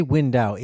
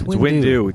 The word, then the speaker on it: Windu: One lightsaber is clearly Mace Windu's, and the other lightsaber... – For Christ's sake, I've listened to you say Windu. – It's Windu.